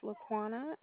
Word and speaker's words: Laquana: My name is Laquana, L-A-Q-U-A-N-A.